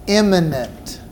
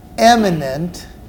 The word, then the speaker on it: immanent: Number two, under attributes of God's nature, God is immanent. Let me spell that for you. It's I-M-M-A-N-E-N-T. Immanent. It's not eminent, E-M-I-E-N-T.